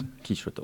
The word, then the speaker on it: Don Quichotte: Et nous recevons comme deuxième plateau un spectacle qui se déroule à la Vista, Don Quixote, ou Don Quichotte, je ne sais pas comment il faut le prononcer. – Don Quichotte, oh là, attention, Don Quichotte.